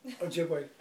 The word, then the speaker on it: Ojibwe: The Heron fit because of the connection with the myths of the I can never pronounce Ojibwe. – Ojibwe. – Ojibwe.